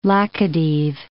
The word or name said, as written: Laccadive Islands